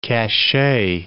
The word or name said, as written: cachet